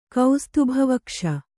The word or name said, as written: ಕೌಸ್ತುಭವಕ್ಷ